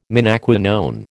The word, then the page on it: menaquinone: American English